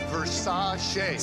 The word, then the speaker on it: Versace: Pronounced Versace.